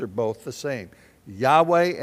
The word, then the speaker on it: Yahweh: The word Yahweh is how a Hebrew in most Hebrews today would pronounce, if they were to pronounce God's name, it would sound something like Yahweh.